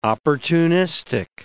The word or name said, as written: opportunistic